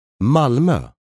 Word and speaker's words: Malmö: Malmö.